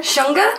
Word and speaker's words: Shunga: Or Shunga. – Yeah. How'd you say that again? – Shunga? – Shunga.